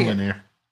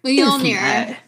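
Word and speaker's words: Mjölnir: Next, say Millionaire. That is not it. – Mjolnir. Mjolnir. You don't pronounce the J. It's like a Y sound.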